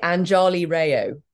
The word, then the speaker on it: Anjali Rao: It's usually Anjali Rao. Oh, no, yeah. It doesn't rhyme with mayo. It rhymes with wow.